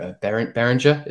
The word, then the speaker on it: Behringer: Behringer, is it? – Yeah, that's right.